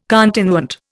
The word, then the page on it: continuant: Indian